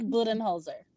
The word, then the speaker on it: Budenholzer: It's Mike Budenholzer. – Oh, okay. – Did I say that right, Brent? – Yeah. Well done.